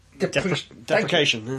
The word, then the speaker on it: deprecation: Again, it's a wonderful self-desperate... – Deprecation. – Thank you. I can't pronounce it.